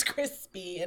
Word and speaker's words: Chrispy: And my name is Crispy. And that's spelled C-H-R-I-S-P-Y.